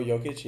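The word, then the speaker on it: Jokić: Time after the game, you see how, I can't say his name, Jokic. – Oh, Jokic, yeah.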